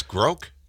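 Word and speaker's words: grok: Pronounced like broke. – Oh, it's groke.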